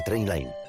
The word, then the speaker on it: Trainline: Se escribe mejor precio garantizado. Se pronuncia Trainline.